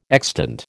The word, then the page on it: extant: American English